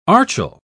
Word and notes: archil: British English

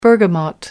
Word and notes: bergamot: American English

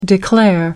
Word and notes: declare: American English

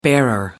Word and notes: bearer: American English

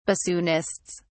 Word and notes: bassoonists: British English

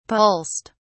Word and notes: pulsed: British English

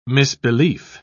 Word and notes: misbelief: British English

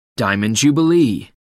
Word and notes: diamond jubilee: American English

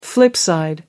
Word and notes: flip side: American English

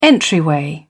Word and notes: entryway: British English

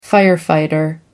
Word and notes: firefighter: American English

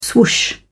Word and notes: swoosh: British English

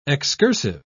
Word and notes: excursive: British English

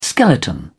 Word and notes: skeleton: British English

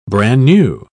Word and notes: bran-new: British English

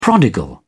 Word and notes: prodigal: British English

